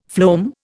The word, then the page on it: phloem: Indian